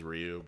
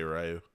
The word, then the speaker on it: Ryu: Yeah, I think actually the correct pronunciation is Ryu, but Americans say Ryu.